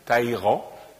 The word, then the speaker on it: Talleyrand: Et 13 mois, nous avions abordé le premier volet de la conférence sur Talleyrand.